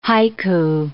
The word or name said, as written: haiku